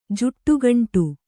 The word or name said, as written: ಜಡೆಗೊಂಡೆ